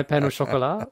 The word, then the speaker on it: pain au chocolat: How to pronounce pain au chocolat.